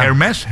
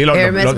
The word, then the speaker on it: Hermès: ¿Cómo se dice? – Exclusiva. – Hermès. – Sí, Hermès, mira, de Birkin está en 13.500 arrancando.